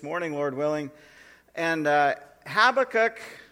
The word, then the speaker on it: Habakkuk: And so I looked into it a little bit, and in the Hebrew, if you were getting an actual perfect uh, pronunciation, the B actually sounds like a V. Did you know that? It's actually Havakuk.